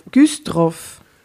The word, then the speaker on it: Güstrow: Wie heißt das Dorf? – Grüsdorf. Güstrow. Wie schreibt man das? G-U-S-T-R-O-W. Güstrow. Güstrow.